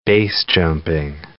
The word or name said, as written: BASE jumping